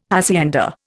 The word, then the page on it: hacienda: Indian